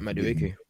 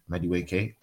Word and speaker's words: Madueke: Madueke. – Yeah, Madueke.